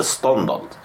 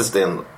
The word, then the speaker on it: Stendhal: Han heter Ståndal. – Ståndal? Ja, Ståndal. Inte Sten. Jag sa inte mm, mm, Okej, okay. Ståndal.